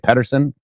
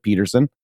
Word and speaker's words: Pedersen: My last name is Pedersen, and people say Pedersen. Phonetically, that's correct, but I pronounce it Peterson because of the Norwegian accent, I guess.